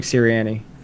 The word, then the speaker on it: Sirianni: Is Sirianni, is that how you pronounce his last name? – Yeah, Nick Sirianni.